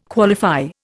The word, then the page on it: qualify: Indian